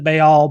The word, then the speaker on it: Baal: of either baal or baal however you want to pronounce that it's um, more faithful to the hebrew to pronounce it baal